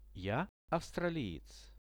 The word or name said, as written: ja avstraliec